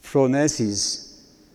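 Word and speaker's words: fronesis (φρόνησις): Que la PH se pronuncia como F, ¿verdad? Como teléfono es, es fronesis.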